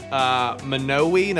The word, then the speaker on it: Monowi: So legitimately, though, it's Monowi, Nebraska, I believe it's pronounced.